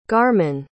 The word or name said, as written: garman